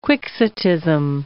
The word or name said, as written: quixotism